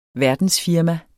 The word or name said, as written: verdensfirma